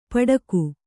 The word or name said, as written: ಪಡಗು